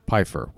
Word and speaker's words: Pfeiffer: Okay, and for the record, yes, his name is pronounced Pfeiffer with that hard P, all right?